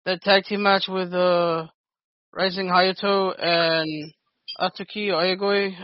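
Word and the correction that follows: Aoyagi: against Oyagi. Come on, bro. No, I watched the match with Dan. – No, I'm trying to be legit. How do you pronounce it? – It's Suki Oyagi. – Oyagi, got it.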